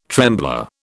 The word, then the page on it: trembler: British English